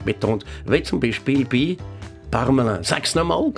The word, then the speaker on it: Parmelin: Die meisten lassen dann das Altone weg und sagen den Namen, wie wenn es ein Finn wäre, mit Ä geschrieben, Parmelin. Und wehe, unser neuer Bundesrat Bundesrat geht künftig mal auf Staatsbesuch auf Deutschland. Dort wird aus «ein» nicht ein, sondern «eng», wie «peng», «parmeleng». Sogar noch schlimmer, Pameleng,